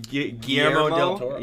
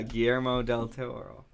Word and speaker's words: Guillermo del Toro: Guillermo del Toro. – Yeah, Guillermo del Toro.